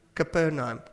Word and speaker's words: Capernaum: Is that how you pronounce it? Capernaum, yes.